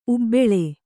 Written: ಉಬ್ಬಾಳು

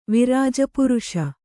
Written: ವಿರಾಜಪುರುಷ

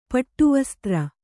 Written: ಪಟ್ಟುವಸ್ತ್ರ

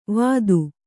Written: ವಾದು

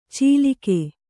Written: ಚಿಲಿಕೆ

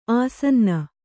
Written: ಆಸನ್ನ

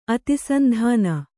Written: ಅತಿಸಂಧಾನ

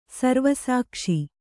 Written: ಸರ್ವಸಾಕ್ಷಿ

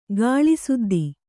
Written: ಗಾಳಿಸುದ್ದಿ

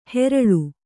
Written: ಹೇರಾಳು

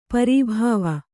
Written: ಪರಿಭಾವ

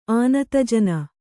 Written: ಆನತಜನ

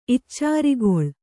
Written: ಇಚ್ಚಾರಿಗೊಳ್

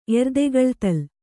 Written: ಎರ್ದೆಗಳ್ತಲ್

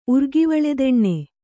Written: ಉರ್ಗಿವಳೆದೆಣ್ಣೆ

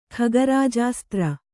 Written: ಖಗರಾಜಾಸ್ತ್ರ

